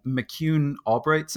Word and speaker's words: McCune-Albright: So you have a condition, you were born with a condition called McCune-Albright syndrome. Is that correct? – That is correct, yes. – Did I say it right even? – You did.